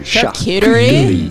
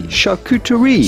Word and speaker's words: charcuterie: Charcuterie. – Charcuterie. Charcuterie. Now in French, you'd say charcuterie. – Charcuterie. – Charcuterie. – But in English, charcuterie.